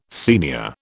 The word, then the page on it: senior: British English